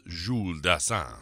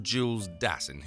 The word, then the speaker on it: Dassin: That has to be pronounced Jules Dassin, right? Nope, it's plain old Jules Dassin, who was born in Connecticut, although he did actually work in France after the Blacklist scandal.